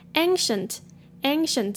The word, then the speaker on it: ancient: 首先，ancient 这边的 c 发 s 的音，ancient。